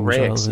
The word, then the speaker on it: RAEX: I kept saying R-A-E-X Games. – Oh, Rake's. People say Rake's. Rake's Games.